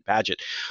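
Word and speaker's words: Bagehot: How do the English pronounce his last name? I'm sorry. I'm going to Bagot. – So there, I as an American have just showed how American I am. I've been referencing him for decades, and I've never once pronounced his name correctly.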